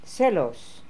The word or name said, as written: Selos